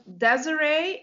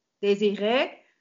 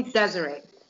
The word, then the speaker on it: Desiree: No, you pronounce it Desiree in English, but it's originally French, Désiré. And well, I'm from Holland, the Netherlands, and here we say Desiree, but you can just call me Desiree.